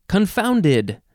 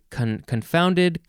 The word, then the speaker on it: confounded: Next is confounded. This is an adjective from the 14th century. You can say confounded, confounded, yeah. Or you can emphasize the first syllable, confounded.